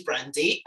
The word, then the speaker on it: Brandie: So my name is Brandie, spelled with an IE.